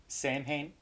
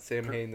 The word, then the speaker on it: Samhain: Isn't it, like, spelled not the way you say it? – S-A-M-H-A-I-N. – Oh, Samhain? – Yeah, if I would have never looked up how to say it, I would have been saying Samhain this whole time.